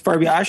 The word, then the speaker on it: Farbiarz: Is that how you say your last name? – Pretty close. Farbiaz.